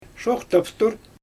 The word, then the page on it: ʃoxtaftur: Xyzyl